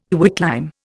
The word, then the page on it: quicklime: Indian